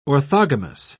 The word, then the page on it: orthogamous: British English